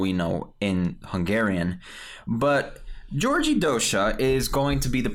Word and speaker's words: György Dózsa: Well, it's spelled G-Y-O-R-G-Y. The O has the two dots on the top, which means the pronunciation is different. But I don't really know. I'm saying Georgi Dosha. It could be Georgi Dosha. Pardon my pronunciation.